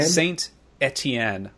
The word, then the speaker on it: Saint Etienne: Saint Etienne.